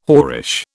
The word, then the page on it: whorish: British English